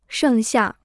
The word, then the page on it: 剩下: shèng xià